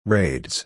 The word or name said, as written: raids